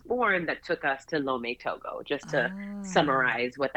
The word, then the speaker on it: Lomé: You were born in Washington, D.C., but you were moved, your family moved and lived in, correct me if I'm saying this wrong, Tomé Logo, is that right? – Yeah, in Lomé, Togo, exactly.